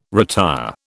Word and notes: retire: British English

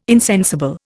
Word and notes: insensible: Indian

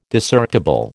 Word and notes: Discerptible: American English

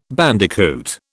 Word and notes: bandicoot: British English

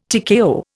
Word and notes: TKO: Indian

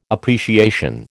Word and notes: appreciation: American English

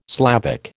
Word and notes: Slavic: American English

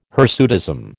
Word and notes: hirsutism: American English

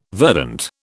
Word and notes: verdant: British English

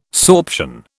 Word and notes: sorption: British English

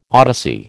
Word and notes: odyssey: American English

